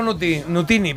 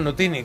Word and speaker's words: Nutini: Bueno, pues sí, Paolo Nutini, perdón, Nutini, que lo he dicho mal.